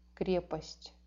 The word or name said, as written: kreposti